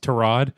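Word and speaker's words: Tyrod: Yeah, he said it's pronounced Tarod, and really? – He said that he said no one has ever asked him before, but since you're going to ask, he told the Cleveland media it's actually pronounced Tarod.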